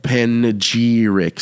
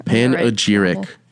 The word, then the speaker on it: panegyric: Panegyric. – So I'm not putting the right emphasis on the right thing. – The emphasis. – Panegyric.